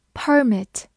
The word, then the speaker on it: permit: 另外，值得注意的是，它也可以做一个名词，表示许可证或者许可，但在这个时候，它的重音放在了前面，读作 permit。